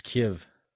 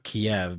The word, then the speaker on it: Kyiv: It's Kiev. – Kiev. – Either way, I think the point of it is it's not Kiev because that's like the Russian pronunciation, right?